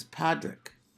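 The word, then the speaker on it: Pádraig: It's not supposed to be P-A-T-T-Y. He says, no, it's P-A-D-D-Y because of the Irish pronunciation of the word Patrick is Padraig. – Yes. – P-A-D-R-A-I-G. And Katrina Balfe said that earlier on the show because that was the one clip I saw of her.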